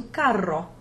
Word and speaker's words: carro: Just think of how doubling the R in the word caro in Italian changes the meaning from dear to carro, crazy.